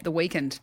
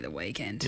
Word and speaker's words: The Weeknd: You're a MacArthur breakfast on C91.3, because that's how you say it, The Weeknd. – The Weeknd. – Yes. It's actually not The Weeknd. – Well, there's no E in there, is there? So it goes week, N-D. – Weeknd.